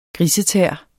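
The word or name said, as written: grisetæer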